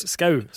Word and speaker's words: Skau: Torbjørn Preus Sku... Hvordan uttaler du det? – Ja, det er Torbjørn Preus Skau, så det er som ditt etternavn egentlig. – Bare med en O? – Bare med en O, ja.